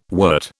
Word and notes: wert: British English

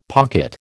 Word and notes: pocket: American English

